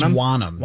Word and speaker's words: Wonnum: I think it's either Wonnum? That or Wonnum. I think Wonnum? It's Wonnum.